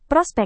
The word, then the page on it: prospek: Indonesian